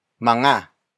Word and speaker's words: mga: But before that, we have mga. Mga. Mga. Mga is spelled as M-G-A.